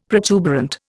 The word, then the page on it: protuberant: Indian